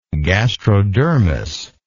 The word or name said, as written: gastrodermis